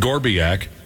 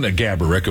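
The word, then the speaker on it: Gáborík: And the sales department approached me and said, hey, you know, this Saturday afternoon, would you like to make an appearance alongside Marion Gaborik, of course, is the real pronunciation, but Josh's uncle liked to make up different pronunciations.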